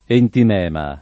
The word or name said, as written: entimema